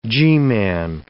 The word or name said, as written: G-man